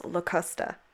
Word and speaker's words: Locusta: According to Google, it is pronounced Locusta. So I'm gonna be saying it, Locusta.